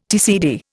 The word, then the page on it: TCD: Indian